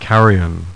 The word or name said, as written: CARRION